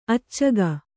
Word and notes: ಅಚ್ಚುಗ: accuga

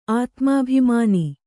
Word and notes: ಆತ್ಮಾಭಿಮಾನ: ātmābhimāna

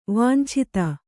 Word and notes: ವಾಂಛಿತ: kannada

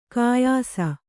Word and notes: ಘಯ್ಸ: ghaysa

